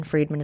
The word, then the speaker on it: Friedman: Friedman is spelled like fried man.